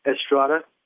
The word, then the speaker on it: Estrada: It's Captain Jacob Estrada, J-A-C-O-B-E-S-T-R-A-D-A.